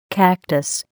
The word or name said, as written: cactus